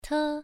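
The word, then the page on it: te: tone 1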